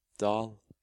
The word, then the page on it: dal: Norwegian